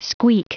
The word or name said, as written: squeak